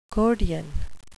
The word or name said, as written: gordian